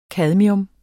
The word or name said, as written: cadmium